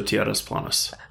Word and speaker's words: Tierras Planas: You just need to roll the R, so Tierras Planas.